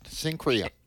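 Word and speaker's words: Cenchrea: Sencria.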